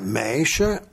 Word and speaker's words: meisje: Je veux bien, mèche, en flamand, ça s'écrit M-E-I-S-J-E.